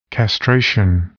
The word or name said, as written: castration